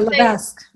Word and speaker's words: Levesque: Yeah, Levesque. – Levesque. Or Levesque or something like that. It's pronounced really weird, not how it's spelled.